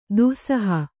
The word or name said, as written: ದಾಸೋಹ